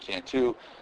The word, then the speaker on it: Canto: Just type in my name, Ted Cantu. Last name is spelled C-A-N-T-O.